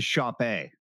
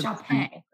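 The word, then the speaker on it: shoppe: It's a shop A. – Shop A. – Which is pronounced just like Dulé pronounces his name. – Oh, so shop A.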